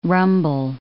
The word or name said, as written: rumble